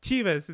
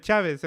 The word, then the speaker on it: Chavez: And he's like, "Yeah, I said Chivas instead of Chavez."